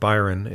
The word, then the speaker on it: Byron: Byron is B Y R O N.